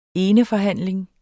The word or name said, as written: eneforhandling